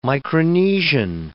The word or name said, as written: Micronesian